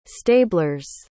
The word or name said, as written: stablers